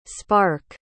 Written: sparke

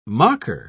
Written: mocker

